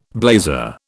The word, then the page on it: blazer: British English